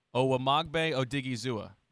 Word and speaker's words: Owamagbe Odighizuwa: Since we're talking about pronunciations, tell me if I'm doing this right ahead of time, Phil. Owamagbe Odighizuwa. – Yeah, I think you're right on it, especially on the last name.